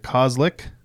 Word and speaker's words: Koslick: Braeden Bumpers from McClintock Distilling, and also first-time guest, Eric Koslick, who I nailed the name. – Nailed it. – Apparently, people for some reason have trouble pronouncing a 6-letter. – Yeah, 2 syllables.